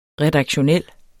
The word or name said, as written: redaktionel